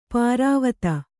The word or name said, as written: ಪಾರಾವತ